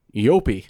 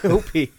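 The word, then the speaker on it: eopie: riding an Yopi. – Thank you, Yopi.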